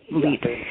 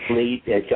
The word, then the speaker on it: Vliet: One was this Dr. Van, I could never, how do you pronounce his name? – Van Vliet. Vliet. Vliet, Dutchman. Yeah, right, and he taught him, right, and he taught him the magic of books.